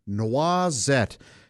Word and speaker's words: noisette: Noisette. It's spelled like noisette, N-O-I-S-E-T-T-E, but it's pronounced noisette, and it means a fillet of meat, or a loin, if you will, of meat.